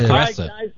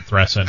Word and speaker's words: Teresa: Well, and like I learned something new today, how to pronounce Teresa the right way. – Yes, you did. – Mother Thressa. – All right, thank you. – I believe it's Saint Thressa now.